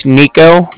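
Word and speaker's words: Niko: Okay, so my name's Nico, that's N-I-K-O.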